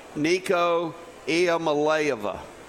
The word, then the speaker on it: Nico Iamaleava: Nico Iamaleava.